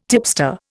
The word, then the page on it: tipster: Indian